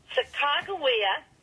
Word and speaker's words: Sacajawea: Sacajawea is the number one accepted pronunciation. And why is that? That's because that's how the captains pronounced her name.